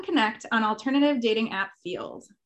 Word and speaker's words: Feeld: So this app is called Field, and I had to Google how to say it correctly. So F-E-E-L-D is the name.